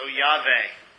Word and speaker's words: Yahweh: He's saying, I am Yud-Heh-Vav-Heh, which we pronounce as Yahweh. Most people say Yahweh, but it's not a W, it's a V. Okay? So Yahweh.